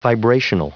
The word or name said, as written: vibrational